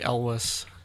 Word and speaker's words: Elwes: Cary Elwes.